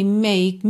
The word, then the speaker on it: make: Ooh, baby, make me know you love me so. And then, sem mistério aqui, né? Ooh, baby, make me know. Make, make. Atenção que o E final não é pronunciado.